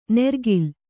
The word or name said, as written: ನೆರ್ಗಿಲ್